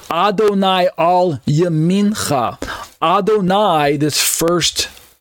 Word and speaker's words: Adonai: Adonai versus Adoni. It's the vowel sound at the very end, the second syllable vowel sound.